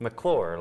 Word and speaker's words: McClure: Oh, wait, before you say it, how do you say McClure. It? – McClure, like that's how I say it. He says McClure.